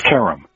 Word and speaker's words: Kerem: It's pronounced Kerem. – Kerem, okay. – And I'm told that that's an Americanized mispronunciation of the original, but it works for us.